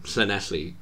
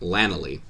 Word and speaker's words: Llanelli: I've met people in Cardiff who call it Llanelli because they're not even trying. Or Llanelli, which is my favorite.